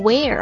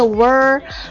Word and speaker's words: were: 顺便讲一下， am 和 is 它的过去式呢是 was，然后这个复数 are 它的过去式呢是 were，很多同学把它读成 where 啊，不是啊，它是一个 were 啊，是一个 wu 加上一个耳化音 were。